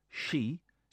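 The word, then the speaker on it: she (四): In Japan, the number four, she, sounds like the word for death.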